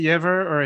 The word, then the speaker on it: Jever: Jever. – I would say Jever or Hever because the J in German is more of a, like an H or a Y.